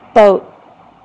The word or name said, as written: boat